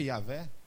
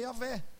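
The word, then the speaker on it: Yahweh: Quando você fala assim, você lê lá na Bíblia, lá, Deus se revelando, eu sou, aquilo ali no hebraico é Yahvé. Só que são quatro consoantes, não tem pronúncia. É uma pronúncia que se perdeu na história.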